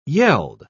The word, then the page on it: yeld: British English